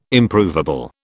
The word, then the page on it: Improvable: British English